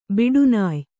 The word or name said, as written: ಬೇಟೆನಾಯಿ